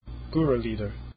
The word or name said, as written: gurrelieder